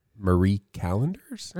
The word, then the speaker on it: Marie Callender's: It's, I mean, you'll pay more than that for Marie Callender's. Uh-huh. Did I say it right? – Uh-huh.